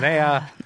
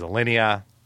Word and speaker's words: Alinea: I said Alinea. It is Alinea.